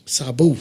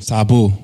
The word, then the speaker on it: Saboo: Can you say that again? – Sabu. – Can you spell it? You know how to spell it? – S-A-B-O-O. – Sabu.